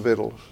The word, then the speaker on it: victuals: That's one of those words that looks like it ought to be pronounced one way, but isn't. You thought it was just rednecks that called them victuals, but that's the way it's really pronounced.